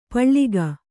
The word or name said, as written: ಪಳಿಗೆ